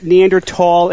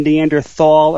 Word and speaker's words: Neanderthal: For the record, Neanderthal and Neanderthal are both accepted pronunciations, so please don't send emails on that.